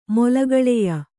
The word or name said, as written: ಮೊಲಗಳೆಯ